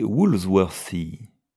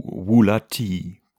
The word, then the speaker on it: Woolsworthy: Le nom du pasteur était Woolsworthy, ou Woolatty, comme on le prononce dans ce pays.